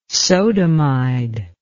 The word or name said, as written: sodamide